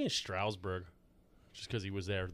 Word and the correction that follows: Strasburg: Strasburg. – There's Harper's no U in it. – Bryce Harper. I mean, Steven Strasburg. – It's not a Stroud. There's no U in it.